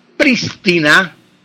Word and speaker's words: prístina: Lo correcto es que la palabra lleva tilde en la última, lleva tilde en la primera sílaba. En la primera sílaba. Prístina, es lo correcto, prístina, con acentuación esdrújula.